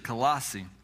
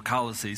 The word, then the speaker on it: Colossae: In the book of Colossians, Paul is writing to the church at Colossae, or Colossae, some people pronounce that way, for the main purpose of refuting a heresy that had begun to arise in the young church.